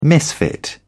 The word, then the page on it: misfit: British English